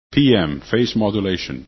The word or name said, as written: PM